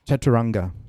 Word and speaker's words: Chaturanga: Chaturanga.